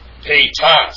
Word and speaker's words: agapetas: Next, we have the form agapetas. Agapetas. Agapetas, actually. Agapetas. And if we turn to, yeah, I got the accent in the wrong place. Pietas is the correct way to pronounce that.